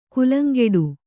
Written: ಗೆಲ್ಲಂಗುಡು

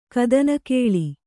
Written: ಕದನಗಲಿ

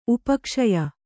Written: ಉಪಕ್ಷಯ

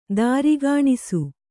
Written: ತಾರ್ಕಣಸು

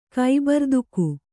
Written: ಕೈಬರ್ದುಕು

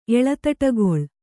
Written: ಎಳತಟಗೊಳ್

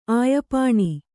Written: ಆಯಪಾಣಿ

